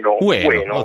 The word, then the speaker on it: Ueno: E questo parco di cui parli, dimmi tu la pronuncia esatta, perché io l'ho... – Ueno. – Ok, allora l'ho detto giusto.